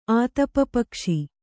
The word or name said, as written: ಆತಪಪಕ್ಷಿ